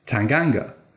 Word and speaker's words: Tanganga: And he makes a beeline for Japhet Tanganga and says, "How do I pronounce your name?" And Tanganga replies, "Tanganga."